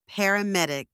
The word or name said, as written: paramedic